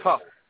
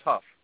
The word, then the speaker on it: Hough: particularly Julianne Hough or Howe, however you pronounce her name. – I think it's Hough. It's Hough.